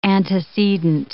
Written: antecedent

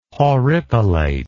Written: horripilate

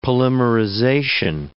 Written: polymerization

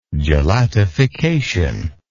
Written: gelatification

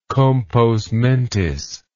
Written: compos mentis

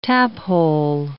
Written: taphole

